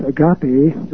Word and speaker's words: agape: And then there is a third kind of love, which biblical scholars will be familiar with, agape, or agape is sometimes pronounced, which is the pure form generous love that wants nothing in return, that seeks absolutely no return whatsoever.